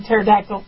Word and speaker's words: pterodactyl: because the P is silent. The P in pterodactyl.